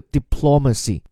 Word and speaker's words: diplomacy: Bacow，还是对这个人的介绍，说他被选择 for something，就表示的后面原因了，是由于他的外交和领导技能，diplomatic 外交的，它的名词形式 diplomacy，注意它的这个发音的变化，diplomatic 重音在 ma，而这个 diplomacy 发音则从头开始啊，由重转轻，diplomacy。